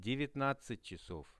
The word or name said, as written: 19-chasov